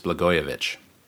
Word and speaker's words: Blagojevich: He was the one I was saying last time that I didn't know how to pronounce his name because it's spelled Blagojevich, and although I knew that wasn't it. So it apparently is Blagojevich.